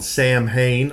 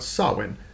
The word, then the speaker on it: Samhain: So right off the bat, Halloween has its origins in a Celtic festival called Samhain. It's not called Samhain, although it is spelled that way. It's pronounced Samhain.